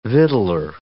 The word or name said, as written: victualer